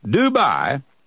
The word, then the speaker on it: Dubai: It's spelled D-U-B-A-I. I remember the first time I saw it, we had to do some checking to know how to pronounce it. It's pronounced just Dubai, okay?